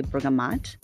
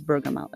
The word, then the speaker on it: bergamot: So bergamot, which is spelled P-E-R-G-A-M-O-T. When I first saw it, I used to say bergamot, and then maybe that's how you pronounce it. Let me know. What do you think? But then I was told, no, it's bergamot.